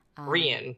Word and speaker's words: Rian: Rian, but he's spelled like Ryan Johnson.